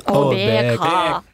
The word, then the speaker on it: Обека: Или Обека? Где ударение на две буквы? – Обека. Ка.